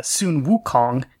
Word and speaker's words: Sun Wukong: Yes, when he begins to study uh, Taoism, he is given this name, the name uh, Sun Wukong the original Chinese pronunciation of Son Goku. You know, same characters pronounced differently across different cultures, but Anyway, he's given this name by his religious instructor, and so accordingly, it has a religious meaning.